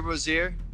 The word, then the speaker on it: Rozier: Terry Rozier.